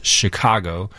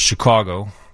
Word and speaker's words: Chicago: They gave four acceptable pronunciations. There's Chicago. There's Chicago. – Chicago. – There's Chicago. – Chicago. – And Chicago.